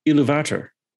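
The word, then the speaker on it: Ilúvatar: Iluvatar, right? – Yeah. That's not how it's pronounced by the guy who reads it. It's Iluvatar.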